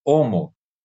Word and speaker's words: Homo: E nós não, o H não tem pronúncia para gente, então não faz sentido esse H ter som, né? O mais correto é nós falarmos Homo, né? E ignorar a pronúncia do H, porque tem esses regionalismos que são absolutamente corretos.